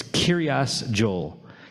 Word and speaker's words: Kiryas Joel: Kiryas, which is a Yiddishization of the word Kiryat, which means village of, and Joel is actually, interestingly, an English pronunciation of his name, Yoel, or Yoelish, or Yoeli.